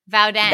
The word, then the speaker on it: Voudon: Vauden. – Vauden? – Vauden. Yeah. – Vauden. – It's spelled Voudon, but pronounced Vauden.